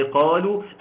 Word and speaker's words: قالوا: لا ينطق لا وصلا ولا وقفا ولا موجود أصلا. ده ألف بيكون موجود علشان واو الجماعة. ده ألف أنت ما تبص وانت ماشي هتلاقي قالوا.